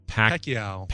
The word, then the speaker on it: Pacquiao: How do you say the other guy's name? – Pacquiao. – Pacquiao, is that how you say it? Mm-hmm.